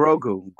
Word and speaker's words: Grogu: The child, Morgu, am I saying his name right? What'd you call him? Is it Morgu, right? – Mor- no, Grogu. Grogu. – Grogu with that hard M.